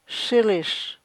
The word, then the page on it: shilish: chukchansi